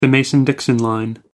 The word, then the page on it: mason-dixon line: American English